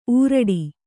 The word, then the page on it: ಊರಡಿ: Kannada